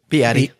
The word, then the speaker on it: Beatty: It's sort of Beatty, the way he says it. Beatty. It sort of almost rhymes with deity, which is it's a very peculiar pronunciation.